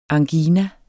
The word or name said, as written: angina